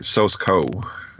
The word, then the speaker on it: Sosko: And this is starring Rebecca Rittenhouse, Chelsea Alden, and Betty Gabriel, directed by Steven Soska, or Sosko. – Is he one of the, like, related to the Soska sisters? – No, I'm sorry, mispronunciation. Sosko.